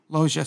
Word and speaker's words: Loggia: Robert Lugia. Loggia, sorry. – I only know after a Pokemon. – The only reason I know his name is Robert Loggia, pronounced that way, because there was a commercial for...